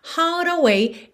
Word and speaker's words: heart away: Então, heart away é a pronúncia que a gente tem aqui para esse trecho. To give my heart away. Heart away. Se você já tem o costume de enrolar a língua no heart, não tem problema nenhum.